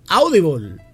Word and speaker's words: Audible: Se pronuncia Audible y es una plataforma de podcast y de audiolibros.